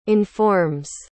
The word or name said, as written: informs